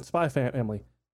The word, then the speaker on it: Spy x Family: Spy Family. Found out, yeah. you don't pronounce the X.